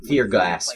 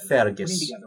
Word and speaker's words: Fergus: Fear Gus. – Fergus Urquhart. – It's you, Fear Gus. Fear Gus. – But it's pronounced Fergus.